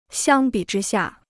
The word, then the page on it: 相比之下: xiāng bǐ zhī xià